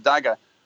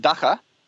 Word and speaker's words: dagger: But I just want to say, with pronunciation of the word dagger, whatever you do, don't call it dacha, because in Afrikaans you pronounce your G like a ch, you know.